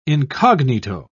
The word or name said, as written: incognito